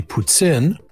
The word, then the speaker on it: poutine: But if you actually want me to say it in French, it's actually poutine. That's how you say it correctly.